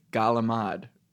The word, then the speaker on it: galamad: Well, I'm supposed to, it's not fried calamari, right? – Correct. – It's galamad.